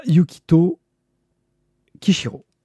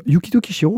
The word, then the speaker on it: Yukito Kishiro: Euh, euh, Yukito Kishiro. Pas mal. – Voilà, Yukito de suite, mais le Kishiro pas du tout.